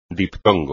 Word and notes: diptongo: espagnol

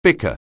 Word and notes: bicker: anglais

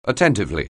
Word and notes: attentively: anglais

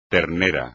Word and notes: ternero: espagnol

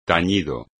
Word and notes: tañido: espagnol

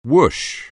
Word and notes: whoosh: anglais

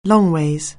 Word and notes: longways: anglais

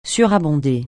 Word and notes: surabonder: French

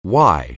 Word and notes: why: anglais

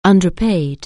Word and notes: underpay: anglais

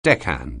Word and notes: deckhand: anglais

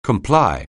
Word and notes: comply: anglais

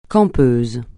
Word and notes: campeuse: francais

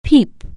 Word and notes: peep: anglais

